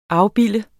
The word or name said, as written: afbilde